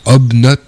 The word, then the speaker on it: Hobknobs: Alors, Obnot, on va dire ça comme ça. Ça s'écrit H-O-B-K-N-O-B-S.